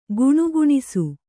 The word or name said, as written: ಗುಣಗುಣಿಸು